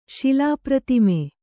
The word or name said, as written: ಶಿಲಾಪ್ರತಿಮೆ